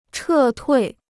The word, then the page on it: 撤退: chè tuì